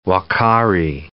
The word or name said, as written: uakari